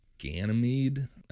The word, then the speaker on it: Ganymede: So, and he said that the aliens lived in a galaxy called Ganymede. I don't really know how to pronounce that. – Pretty close.